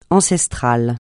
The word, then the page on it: ancestral: francais